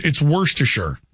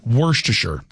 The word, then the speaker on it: Worcestershire: It's Worcestershire. That's how it's pronounced.